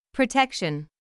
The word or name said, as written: protection